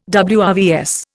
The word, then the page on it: WRVS: Indian